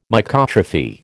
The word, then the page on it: Mycotrophy: American English